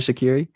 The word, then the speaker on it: Shaqiri: Jordan Shaqiri.